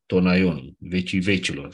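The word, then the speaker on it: Aion (αἰών): După pronunția din neogreacă, unde ai se pronunță E, îl avem Eon.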